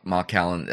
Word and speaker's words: Macallan: Macallan. Emphasis on the ma, usually.